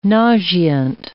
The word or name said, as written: nauseant